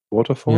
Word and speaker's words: Waterford: Waterford, wenn ich es richtig ausspreche. Waterford? – Ja.